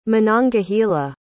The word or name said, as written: monongahela